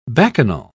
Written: bacchanal

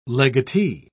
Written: legatee

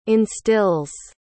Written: instills

